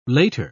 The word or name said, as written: later